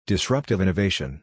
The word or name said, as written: Data-Driven Decision Making